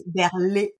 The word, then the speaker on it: Berlion: I know it's not that easy to pronounce. In French, it's Berlion because you even have an accent. I cannot put it.